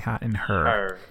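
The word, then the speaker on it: Hot in Herre: If I believe I'm pronouncing that correctly, Hot in Her. – Yeah, that's it.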